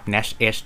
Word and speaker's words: NASH: n a s H